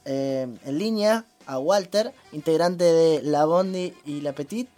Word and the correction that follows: Bodie: Es, sí, es Body y La Petit Rivero.